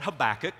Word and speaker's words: Habakkuk: Is it Habakkuk? Is it Habakkuk? Is it Habakkak? Like, people don't know. My Hebrew professors called it Habakkuk.